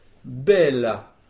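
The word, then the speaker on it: bela: Bella. – Bella. – Bella. – Bella. – C'est beau. Très bien. C'est aussi beau que ta façon de prononcer, car tu as bien mis l'accent tonique sur le bella.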